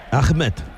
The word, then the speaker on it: Ahmed: Yo todavía estoy pensando en cómo ha pronunciado Ahmed. – Ahmed. – Me ha parecido, claro, maravilloso. – Sí. Como se debe pronunciar.